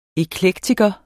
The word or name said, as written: eklektiker